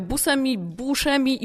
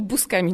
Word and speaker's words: Buscemi: Ona sama chyba tak nie do końca też to prostuje, ale to jest trochę tak jak ze Steve'em Buscemim, którego się wymawia Buscemi i Buscemi nawet gdzieniegdzie.